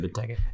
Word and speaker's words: Benteke: I'm probably gonna butcher his name, Benteke, is that how you say it? Okay. – Christian Benteke.